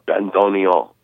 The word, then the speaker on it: bandoneon: And how do you say the accordion instrument, bandoneon? – Bandoneon. – Bandoneon. Yeah. I have to see it written out so to know how to say it right.